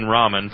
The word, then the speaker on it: ramen: Squishy18 says, is the A in ramen pronounced ramen or ramen? – Ramen. – Neither. – Ramen. – Or the A in ramen is pronounced ah. Ramen as a whole is pronounced ramen.